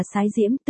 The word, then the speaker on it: Sái Diễm: Chữ Hán, cái cũng đọc là Sái Diễm, tự Chiêu Cơ.